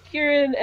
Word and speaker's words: Kieran: You see Sierran and Osen. – Like, totally mangled pronunciation, but sure, that's good too. – Yeah, that's as far as I can pronounce it. – Yeah, it's Kieran and Ocean.